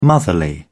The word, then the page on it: motherly: British English